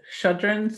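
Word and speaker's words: Chödrön: And I always say her last name wrong, but it's Chodron, C H O D R O N, Pema Chodron.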